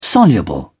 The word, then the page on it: soluble: British English